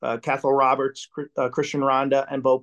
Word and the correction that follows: Cathal: Yeah, it's actually pronounced Cahal. – Cahal. Cahal Roberts. Yeah, it's almost like you got something stuck in your throat.